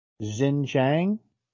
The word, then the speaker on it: Xinjiang: Xinjiang. Xinjiang. – Xinjiang, yeah, that's the province I was talking about with the Uyghurs, I suspect. Xinjiang, yeah.